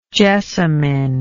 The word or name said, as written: jessamyn